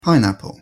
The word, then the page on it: pineapple: English